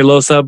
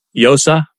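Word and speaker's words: Llosa: Some people say Loza, but technically Yosa. But Yosa? Either one's fine. Yeah, the double L is a Y sound, yeah.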